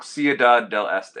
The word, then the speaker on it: Ciudad del Este: I think it's, I'm probably saying this wrong, Ciudad del Este or something. – Oh, yeah, you said that right.